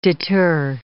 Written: deter

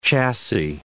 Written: chassis

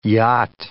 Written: yacht

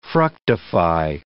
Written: fructify

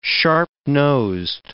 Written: sharp-nosed

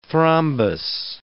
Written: thrombus

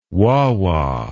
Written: wah-wah

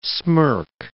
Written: smirk

